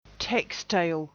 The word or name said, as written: textile